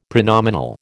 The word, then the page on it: pronominal: American English